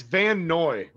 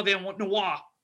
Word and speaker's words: Van Noy: And it's Van Noy. Read a damn book. – It's spelled Noir. That's how I thought it was always pronounced. I always say Kyle Van Noir. – Over his whole career, I've never heard anyone call him that.